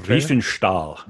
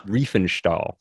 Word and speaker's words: Riefenstahl: Is that Riefenstahl? – Riefenstahl.